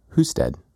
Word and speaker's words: Husted: Husted. Like usted in Spanish. – Oh, you're saying who-stead instead of Husted.